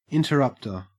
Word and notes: interrupter: Australian English

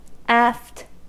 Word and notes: aft: American English

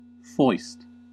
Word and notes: foist: American English

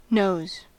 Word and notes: knows: American English

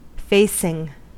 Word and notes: facing: American English

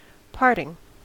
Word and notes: parting: American English

